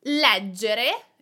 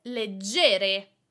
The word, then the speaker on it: leggere: Leggere, it means to read, and the accent is on the first E. And leggere, the accent is on the penultimate E, and it means light in its plural form.